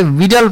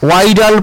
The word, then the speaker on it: Widal: এনারা একটা পরীক্ষা করেন, যে পরীক্ষাটাকে ভিডাল পরীক্ষা বলে, অনেকে উচ্চারণ ভুল করে এটাকে ওয়াইডাল পরীক্ষাও বলে, WIDAL, কিন্তু উচ্চারণটা ভিডাল টেস্ট।